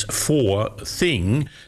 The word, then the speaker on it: Four Thing: It's Four Thing. Now, they say there's a handy pronunciation memory aid from the company. It's really for things, as in for things that matter.